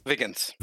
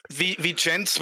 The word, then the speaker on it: vigens: Vigens would be correct, because it's Latin, but it's so hard to pronounce, and Viggins is just easier.